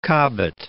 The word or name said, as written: Cobbett